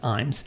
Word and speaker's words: Ijames: Imes, by the way, is spelled... I-J-A-M-E-S. It looks like I, James, but it's pronounced Imes.